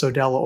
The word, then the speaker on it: Sodelo: And I should probably mention that it's spelled S-O-D-E-L-O. So it's Sodello Orchestra.